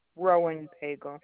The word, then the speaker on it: Roennpagel: It's Robin Roenpegel. – R-O-B-I-N? – Yeah. – And what was the last name? Spell it for me. – R-O-E-N-N-P-A-G-E-L. – Pronounce that? – Roenpegel. – And you said that was R-E-N-N-P-A-G-E-L? – Right.